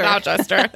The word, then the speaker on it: Gloucester: It might be Gloucester, but I feel like that's... – Okay, Gloucester. Your, I like that. – Maybe. Yeah. – The word that's spelled that looks like Gloucester. – Gloucester. – Gloucester. There it is.